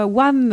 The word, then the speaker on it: Wham!: Allez, là, qui nous a proposé Wham! Last Christmas? – Je plaide coupable. – Ok, alors c'est un son de Marion, j'espère que je l'ai bien présenté. – Ouais, t'as bien prononcé, c'est bon.